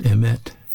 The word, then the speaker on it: emet: But this Hebrew word for truth, it's pronounced emet, E-M-E-T.